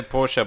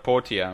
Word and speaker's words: Portia: I keep wanting to say Portia, but Portia. – Adding that extra syllable is killing me.